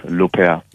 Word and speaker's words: Laupert: Ja, beziehungsweise ist es einfach so, mein Nachname einfach auf Französisch, das ja schön klingt, ausgesprochen. Also quasi Laupert als Lopez.